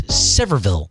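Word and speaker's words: Sevierville: but we just made it up to, by the way, thank you to our listener who just called up and said the way that you pronounce it is Severville.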